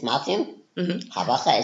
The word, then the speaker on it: Martin Habacher: My name is Martin Habacher, is it correctly pronounced? – German names can be hard.